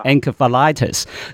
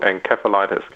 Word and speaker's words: encephalitis: 佢用唔係用個 C 音喎，用 S 音係 encephalitis。咁我以前我就自己讀咧係 encephalitis 嘅。咁其實究竟係咪你呢兩個讀音咧，真係有個係正確，一個唔正確嘅咧？ – 都 OK 嘅，其實都冇乜所謂嘅。我哋醫生一般就係讀佢做 encephalitis 嘅。